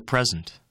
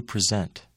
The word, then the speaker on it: present: In the following words, the nouns are stressed on the first syllable. The verbs are stressed on the second syllable. Repeat these words. – The present. To present.